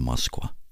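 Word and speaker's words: Moskva (Москва): Jag bara kommer ihåg att Moskva ser ut som att det stavas Mokba. Men det uttalas tydligen Moskva.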